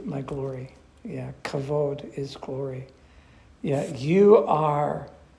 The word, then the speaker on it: kavod: Now, let's look at this one word here, pronounced kavod, kavod.